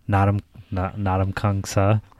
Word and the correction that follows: Ndamukong Suh: Ndamukong Suh? – Yeah.